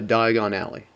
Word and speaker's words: Diagon Alley: and uh, Diagon Alley.